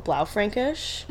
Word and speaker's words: Blaufränkisch: There's another one that I like called Blaufränkisch, which is a mouthful, but very, very good.